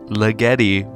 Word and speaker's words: Ligeti: I'm really emphasizing Ligeti because I was told by a friend, my friend Caleb, that it is pronounced Ligeti, which sounds like an American mispronouncing a European name.